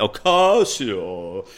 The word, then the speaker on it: Ocasio: Ocasio. Got to say it right. Ocasio.